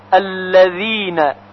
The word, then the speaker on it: الذين: و اما کلمه الذین، الذین با حرف ذال نوشته میشه و با ذال هم باید خونده بشه.